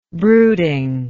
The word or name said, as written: brooding